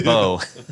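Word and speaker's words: Beau: Plays the role of, it's not pronounced Bew, Patrick. It's called, it's Bo.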